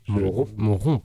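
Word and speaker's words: Saint-Amand-Montrond: Je voulais rappeler que vous seriez le 24 octobre à Saint-Amand-Montron. – Monron, excusez-moi, j'écorche.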